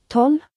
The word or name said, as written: 12